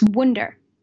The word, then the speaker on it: Wunder: Just make sure the name is spelled right, S-A-M-I-W-U-N-D-E-R. So it's Wunder, the German word for wonder. And you're also welcome to find me on Facebook as Sami Wunder.